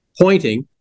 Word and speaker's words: Poynting: His name was Pointing. It's spelled with P-O-Y-N-T-I-N-G. And it's a British name, Pointing.